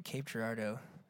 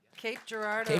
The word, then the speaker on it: Cape Girardeau: Is from Cape Girardeau, Missouri. I got it right. Yeah. – Cape Girardeau.